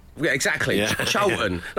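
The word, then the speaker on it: Chorlton: And I was like, whoa, it's Cholton. don't you dare pronounce it like it's spelt. It's Cholton.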